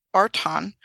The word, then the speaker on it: Rtanj: And the pyramids there, not only in Bosnia, but we visited one in Serbia called Artan, which is spelled R-T-A-N-J, which is a pyramidal-shaped mountain.